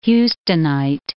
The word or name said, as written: Houstonite